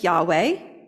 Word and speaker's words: Yahweh: We often pronounce it Yahweh.